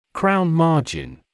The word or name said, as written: crown margin